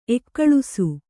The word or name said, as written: ಏಗೊಳಿಸು